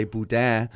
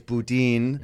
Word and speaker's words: boudin: Coming from Canada, I always want to say boudin, right. – but it's boudin, mm. the way locals say it.